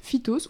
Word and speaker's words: phytos: Alors la phytothérapie, ça vient du grec phytos ou pythos, comment ça se dit? Tu fais du grec? – Ça dépend comment ça s'écrit. – P-H-Y-T-O-S. – Je dirais phytos.